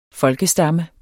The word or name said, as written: folkestamme